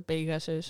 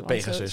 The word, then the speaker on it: Pegasus: Alleen zal ik hem dan uitspreken als de Pegasus. – De Pegasus.